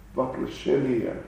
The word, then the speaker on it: воплощения: Istochnik Vasya Vaplajenia How do you say it? Vaplashenia. Vaplashenia.